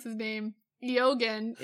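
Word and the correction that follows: Eoghan: is an Irish name, and it's actually pronounced Owen.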